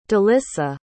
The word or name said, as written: delyssa